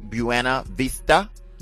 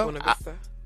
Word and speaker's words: Buena Vista: And the kickoff is in Lake Buena Vista, Florida. – Buena Vista.